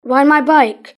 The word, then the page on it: ride my bike: British English